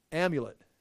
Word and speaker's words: amulet: What do you call it? Omelet? How do you say omelet? They're like little scrolls they'd wear around your neck or something like this, and there's little words written on it in Hebrew. And it's, say that? Amulet.